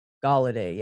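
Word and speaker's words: Golladay: Galloway? – Isn't it Galladay? – Galladay, yeah. See, I said it right the first time, and then I questioned myself.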